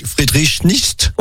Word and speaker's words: Friedrich Nietzsche: Voilà, Friedrich Nietzsche.